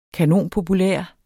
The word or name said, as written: kanonpopulær